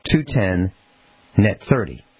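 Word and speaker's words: 2/10 net 30: Glass, there's a term, if you have an account with a company, it's read like this, the number 2 slash the number 10 space the letter N slash 30. And it's pronounced 210 net 30.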